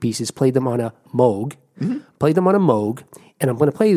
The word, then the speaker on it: Moog: It's pronounced Moog, often anglicized to Moog.